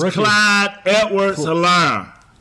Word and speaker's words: Clyde Edwards-Helaire: But his name is Clyde Edwards-Helaire.